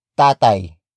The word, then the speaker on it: tatay: Sino ang tatay mo? Sino ang tatay mo? Sino ang tatay mo? It means, who is your father? Or, who's your daddy? Word for word, it's sino, ang, tatay, mo.